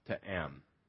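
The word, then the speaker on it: to am (טעם): It's spelled T-A-apostrophe-A-M, to am.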